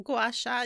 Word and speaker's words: gua sha: One is their Sacred Self-Care Box, which has a rose quartz gua sha. Is that how you say it? – Yeah, I think gua sha.